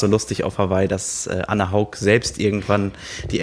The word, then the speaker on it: Anne Haug: Ich weiß inzwischen auch alle Aussprachen von Anne Haug, die bei uns Anne Haug heißt. – Anne Haug? – Im Englischen, das kennen wir auch schon länger. – Annie Haug. – Im Französischen Anorg. Anorg.